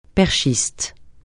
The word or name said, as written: perchiste